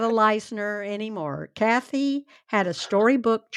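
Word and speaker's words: Leissner: And there he met married a young, determined college student named Kathy Lessner, who wanted to be a school teacher. Oh. – I think that's pronounced Leisner.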